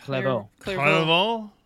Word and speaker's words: Clairvaux: Clairvaux. There's an X there. I know how to pronounce that in French. You just don't.